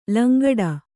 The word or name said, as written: ಲಂಗಡ